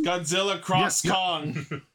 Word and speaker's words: Godzilla x Kong: You don't pronounce the X. – It's like Hunter Hunter. – Wait, yeah. So it's just called Godzilla Kong? – Godzilla Cross Kong.